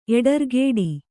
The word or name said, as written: ಎಡರ್ಗೇಡಿ